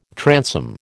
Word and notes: transom: American English

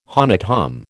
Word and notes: honnête homme: American

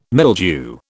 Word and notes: mildew: British English

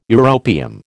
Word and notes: europium: American English

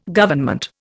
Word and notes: Government: Indian

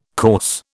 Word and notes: corse: British English

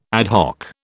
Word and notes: ad hoc: British English

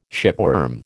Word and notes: shipworm: American English

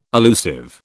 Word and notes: allusive: British English

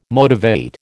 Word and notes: motivate: American English

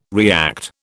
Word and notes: react: British English

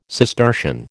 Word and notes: Cistercian: American English

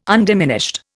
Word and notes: undiminished: Indian

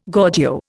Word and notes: Gorgio: Indian

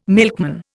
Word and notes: milkman: Indian

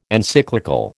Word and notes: Encyclical: American English

